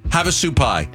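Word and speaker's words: Havasupai: How do you pronounce it? – Havasupi? – Havasupi. – Have a soup pie.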